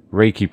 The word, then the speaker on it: Reiki: Reiki practitioner.